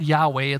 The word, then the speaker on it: Yahweh: And God says in this passage, my name is, you've seen the letters, or you've seen the word Yahweh. It's Y in English, Y-H-W-H, Yod He. It's kind of how you say it in Hebrew.